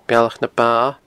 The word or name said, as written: Bealach na Ba